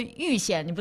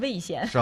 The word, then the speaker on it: 蔚县: 我们还有个蔚县，你不能念成魏县。